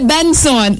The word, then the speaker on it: BNXN: But when you are saying it, when you're pronouncing it, you don't say BNXN. No, you say Benson.